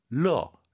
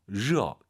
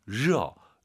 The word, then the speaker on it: 热: 이제 청취자분들 꽤 많은 분들이 热 발음이 잘 안 되는 분들도 있어요. 러, 러 이렇게 해요. 러. 그럼 오히려 L 발음에 가깝고요. 热. 더 혀를 드르륵 말면서 热, 르어라 생각하시고 한 번 더.